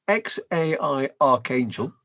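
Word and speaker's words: X Æ A-12: Go on, I think it's worth mentioning, because Elon Musk and Grimes already had a child, their son called XAEA12, which is pronounced XAI Archangel, as if you need to be told that.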